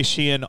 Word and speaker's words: Shein: I don't know how words work, but yeah, so apparently because the way Shein operates is, am I saying that right? It's like two syllables, Shein. – Yeah, because it used to be called Shein Style. – Okay. – And then they cut it to Shein.